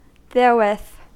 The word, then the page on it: therewith: American English